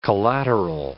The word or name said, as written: collateral